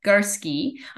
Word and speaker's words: Garske: And my last name is spelled G-A-R, S as in Sam, K-E, Garsky. I'm Leslie Garsky.